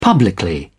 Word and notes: publicly: British English

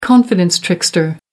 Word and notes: confidence trickster: American English

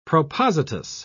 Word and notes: propositus: British English

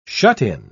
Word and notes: shut-in: British English

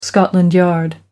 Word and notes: scotland yard: American English

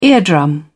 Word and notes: eardrum: British English

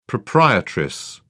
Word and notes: proprietress: British English

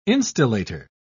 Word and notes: instillator: British English

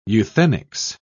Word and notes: euthenics: British English

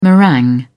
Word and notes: meringue: British English

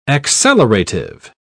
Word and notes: accelerative: British English

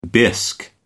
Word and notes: bisque: American English

